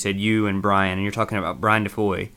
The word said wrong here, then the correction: Foy: Brian Foy. Sorry, don't pronounce the D or you'll get in trouble with him.